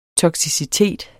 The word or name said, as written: toksicitet